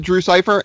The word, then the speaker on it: Drewsipher: But yeah, no, a lot of people pronounce it Drew Cipher.